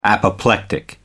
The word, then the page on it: apoplectic: American English